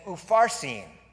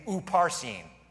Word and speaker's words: Upharsin: Then he says, Perez, which is interesting because on the wall it says, Upharsin. The P and the PH sound are the same in Hebrew, so it's the same letter. It could be pronounced just to benefit you, Upharsin.